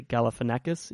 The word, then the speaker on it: Galifianakis: Zach, um, I can't even pronounce his surname properly. Galifianakis, is that it? – That's it.